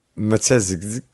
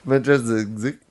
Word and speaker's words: Matyszczyk: Tell me how you pronounce this. MATYSZCZYK. – Matezik. – So I wasn't far off. Matezik. It's fun to say. Chris Matezik.